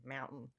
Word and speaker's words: Mountain: There's a T in there. Get rid of your Australian. Mountain.